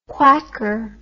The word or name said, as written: quacker